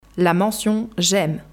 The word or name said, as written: La mention « j’aime »